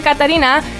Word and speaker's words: Catarina: Astăzi sunt alături de Catarina, sper că am pronunțat bine numele, să mă corectez dacă greșesc. Neața! – L-ai pronunțat perfect, bună dimineața, ce faci?